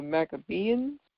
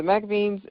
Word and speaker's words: Maccabeans: And it's pretty short and sweet, but in this particular moment in time, we have our political people, the Maccabeans, which, again, I feel like I'm saying it so wrong. It is spelled M-A-C-C-A-B-E. A-N-S, Maccabeans, or Maccabeans. That's what literally looks like, Maccabeans.